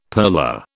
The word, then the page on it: purler: British English